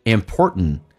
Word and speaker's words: important: Yes, tea is really important. Important 你要记住啊，在美式发音当中 TN 组合，这个 T 是可以不用发音的， T 不发音气流阻塞一下，叫 important， important， really important part of Chinese culture。